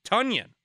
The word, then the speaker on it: Tonyan: The big news out of Packers camp yesterday is that Robert Tonyan's name is actually pronounced Robert Tonyan. Tonyan.